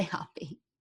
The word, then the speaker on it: Hoppe: In English, they say Hoppe. It's a German last name, actually. And I'm told that they would pronounce it Hopper, but you can say Hoppe.